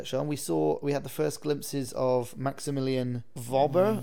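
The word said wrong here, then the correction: Wöber: Verber. – I think it's Verber. – Verber. – I think they're pronouncing it Verber.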